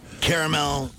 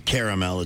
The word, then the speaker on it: caramel: Caramel is caramel. It's a three-syllable word. Caramel is lazy diction. You should fix that.